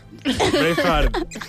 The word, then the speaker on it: Braveheart: Braveheart. Braveheart. Està ben pronunciat.